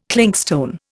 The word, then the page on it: clinkstone: Indian